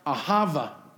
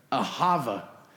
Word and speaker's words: ahava: Well, that love in Hebrew is divine, is pronounced ahava. Let's say that together. Ahava. Ahava. That is love.